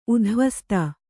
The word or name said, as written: ಉಧ್ವಸ್ತ